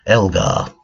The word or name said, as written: Elgar